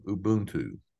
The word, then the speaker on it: Ubuntu: One of the things that you talk about, and this comes from your own African-American heritage, is the power of Ubuntu. Am I saying that correctly? – That's exactly right.